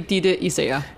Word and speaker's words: Ditte Isager: In Danish, yeah. It will be Ditte Isager.